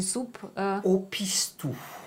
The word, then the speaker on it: Soupe au Pistou: Warum ist denn Ihr Heimatrezept die, wie spricht man es aus, die Soupe? – Au Pistou.